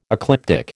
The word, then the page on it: ecliptic: American English